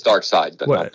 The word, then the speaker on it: Darkseid: Oh yeah, it's Darkseid. – My bad. It's spelled so weird. – It is, but it's even Jack Kirby pronounced it Darkseid. I looked that up today. I wanted to be sure. I was like, God, please don't be Darkseid. That would suck.